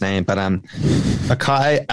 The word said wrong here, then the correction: Ochai: Agbaji. Um, I believe it's um, Ochai Ag- Ajabaji. Okay, there we go.